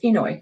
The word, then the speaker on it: Inouye: Inouye.